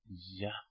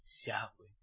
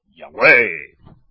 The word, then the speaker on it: Yahweh: Que hay muchos por ahí que no lo pronunciéis bien. Ponéis el acento en la E. Y así termina diciendo Yahweh. Ese no es el nombre.